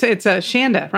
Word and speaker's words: Shanda: We've got Shonda. Actually, it's Shanda. Rhymes with panda.